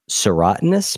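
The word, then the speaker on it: serotinous: One last thing, and then we're going to wrap up, which is, I don't know if I'm going to pronounce this right, but can you tell us about serotonous pine cones? – You did pronounce it right.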